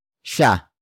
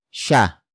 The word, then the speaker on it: siya: It is followed by sha, sha, sha. Sha is spelled as S I Y A it means he or she or the person in focus form.